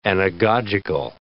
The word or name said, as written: anagogical